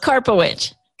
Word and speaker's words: Karpowich: Is that how I say your name? – Almost. Karpowich.